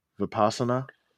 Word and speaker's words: Vipassana: Vipassanaza? Vipassana? – Yeah, it's Vipassana.